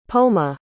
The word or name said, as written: poma